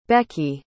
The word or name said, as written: beckie